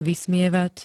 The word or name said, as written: vysmievať